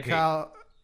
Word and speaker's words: Calliope: I read it as Calliope, but what Calliope. – Calliope. I kept forgetting it. It's one of those things I've read but never heard. And they're like, Calliope.